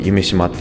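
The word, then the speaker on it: 夢洲: でもしっかり残っているほどでして、まあ、大阪でもあれが見られるとなると非常にワクワクします、まあ、この開催地となる夢島夢という漢字に山沿いのアメリカの州って書いて夢島って読む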